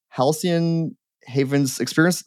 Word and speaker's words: Halcyon Havens Experiences: I'm really, really excited to have Sandra and Sonia on from Halcyon Haven's Experience.